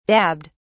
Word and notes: dabbed: British English

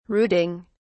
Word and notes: rooting: British English

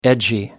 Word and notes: edgy: American English

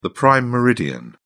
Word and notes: prime meridian: British English

